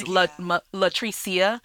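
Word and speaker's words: La'Treecia: So like the fact that my middle name is Latricia. But this is how you spell it. L-A apostrophe capital T. – See? – R-E-E-C-I-A.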